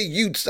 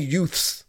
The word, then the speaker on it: Utes: But in saying that, Utah, the Utes, when I say Utes, I don't mean youths, and I'm just mispronouncing it. I say U-T-E-S, the Utes of Utah.